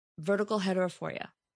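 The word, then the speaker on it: vertical heterophoria: It was the verticalHeterophoria. Did I say it right? – Uh-huh. Yeah. It's binocular vision disorder. And one of the conditions is called verticalHeterophoria.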